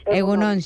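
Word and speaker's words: Egun on: Egunon, sí.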